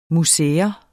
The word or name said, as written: museers